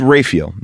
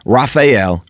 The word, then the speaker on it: Raphael: How is June's last name officially pronounced? It's Raphael, not Raphael, Raphael.